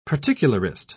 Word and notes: particularist: British English

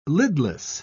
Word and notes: lidless: British English

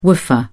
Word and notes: woofer: British English

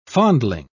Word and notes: fondling: British English

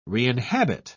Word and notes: reinhabit: British English

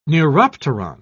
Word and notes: neuropteron: British English

